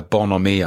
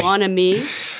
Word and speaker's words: bonhomie: Bonhomie. – Bonhomie, yeah. Bonhomie, I think, is a French word, I guess, meaning a good humor.